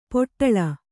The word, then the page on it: ಪಟಳ: paṭaḷa